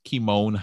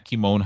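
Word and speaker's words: Kymone: So on any social media platform, Facebook, Twitter, Instagram, LinkedIn, you can find me at Kimone Hines. So I'm going to spell it because my name is commonly misspelled. It's K-Y-M-O-N-E, H-I-N as in Nancy, D as in David, S as in Sam, at Kimone Hines on any social media platform or my website, ideastolife.me, ideastolife.me.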